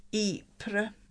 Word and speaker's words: Ypres: Ypres. – What? – It's pronounced Ypres. – All the soldiers call it Ypres. – Not the officers.